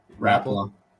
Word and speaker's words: Rapala: So I think I've pronounced Rapala to two different ways already in the same show. What is it? What do they say it in Minnesota? How do they say Rapala? – Rapala.